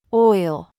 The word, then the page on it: CHOICE: American English